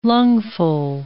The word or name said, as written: lungful